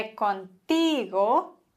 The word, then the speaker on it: contigo: Und wenn wir ein Wort haben, das auf Vokal endet, wie bei contigo, endet auf O, das ist ein Vokal, und in dem Fall wird die vorletzte Silbe betont. Also, me gustaría hablar Contigo.